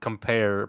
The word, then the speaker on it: compare: From the Italian word compare, pronounced compa, meaning a close friend,